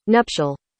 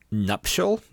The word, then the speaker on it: nuptial: Nuptial. Nuptial, not nuptial. – Nuptial. It's it's the word you're thinking. It's just that apparently this is how it's correctly pronounced.